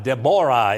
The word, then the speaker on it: Deborah: Deborah is how you say it in Hebrew, and we will learn about this second of the female prophets.